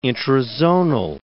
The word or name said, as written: intrazonal